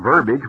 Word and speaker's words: verbiage: And you saw how all that was a lot of talk, a lot of verbiage, which rhymes with garbage,